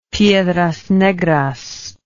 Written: Piedras Negras

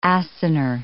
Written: acinar

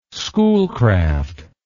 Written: schoolcraft

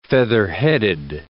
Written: featherheaded

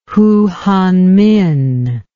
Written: Hu Hanmin

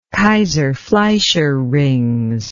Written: kayser fleischer rings

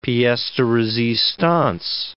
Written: piece de resistance

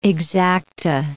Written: exacta

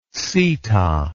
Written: Sita

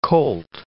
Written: colt

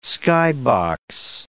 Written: skybox

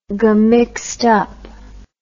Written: gum mixed up

